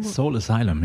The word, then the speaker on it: Soul Asylum: Soul Asylum, ja.